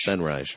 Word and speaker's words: Senreich: Because we're the same generation as Seth Green and Matt Senreich. – Senreich. – Well, that's how he says it.